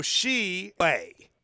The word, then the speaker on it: Shibway: Shibway. So think like sheep. Yes. You go Shibway.